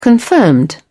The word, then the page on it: confirmed: British English